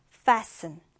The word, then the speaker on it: fasten: 이때 T 발음은 나지 않아요. 그래서 fasten. fasten 이렇게 s 발음만 해주면 되는데요.